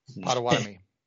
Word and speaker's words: Potawatomi: It actually means waterfall in Potawatomi. – Potawatomi.